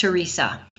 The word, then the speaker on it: Theresia: It sounds like that, but it's just Teresa.